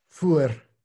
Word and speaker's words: Feuer: Feuer, It's pronounced something like fire. Fire, fire.